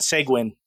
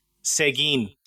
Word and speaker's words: Seguin: So last month, when I was doing some news stories from San Antonio for the News of the Damned, I mispronounced a town name there, and I called it Seguin, but it's Seguin.